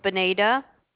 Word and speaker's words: Bonita: My name is Benita. – Can you spell that, please? – B-O-N-I-T-A.